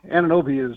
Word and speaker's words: Anunoby: Uh, OG Anunoby, Anunoby is uh, the way I understand it to be pronounced.